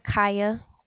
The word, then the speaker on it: Kya: My name is Kaya, K-Y-A.